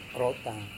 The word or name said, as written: rotang